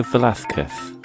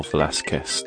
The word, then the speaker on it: Velázquez: And number 20, the Spanish painter was Diego Velázquez, or Velázquez, depending on your Spanish pronunciation.